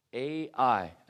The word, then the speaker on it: Ai: And in this tent, he had left city Ai, A-I, and that's how it's pronounced, A-I.